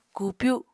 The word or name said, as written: kupyu